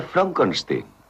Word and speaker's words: Frankenstein: No, Se pronuncia Frankenstein.